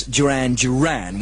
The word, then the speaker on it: Duran Duran: I said Darren Darren and Planet Earth at number 26, when of course it should be, as everybody knows, Duran Duran.